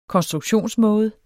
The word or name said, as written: konstruktionsmåde